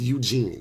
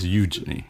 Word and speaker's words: Eugenie: I thought it was Eugene. – No, it's Eugenie. – Eugenie? – Eugenie, yeah.